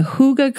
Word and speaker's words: hygge: I hope so, because today's show is all about hygge. It's spelled H-Y-G-G-E, so it looks like you should pronounce it hygge, but the Danes pronounce it hygge,